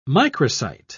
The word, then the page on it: microcyte: British English